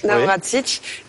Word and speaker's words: Navracsics: Et pour moi, le meilleur exemple, c'est le commissaire hongrois, qui s'appelle donc Tibor Navracsics, j'espère que je le dis bien. – Oui. – Navracsics, qui lui est donc commissaire en charge de la culture, éducation, jeunesse et sport.